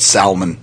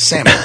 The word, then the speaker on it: salmon: I have a couple of friends that call it salmon. I'm like, no, it's salmon. The L is silent.